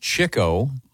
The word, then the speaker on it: Chico: It's pronounced Chico. Really? – I've never heard Chico. – But it's Chico.